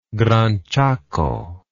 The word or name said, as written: Gran Chaco